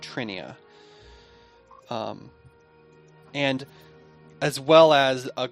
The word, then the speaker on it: Trinia: Trinia. T-R-I-N-I-A.